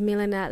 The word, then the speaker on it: Milena: When I'm on the phone with someone who needs to know my name, I say, yes, hi, my name is Milena Vujnić. Well, my first name is Milena.